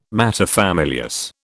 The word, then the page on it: materfamilias: British English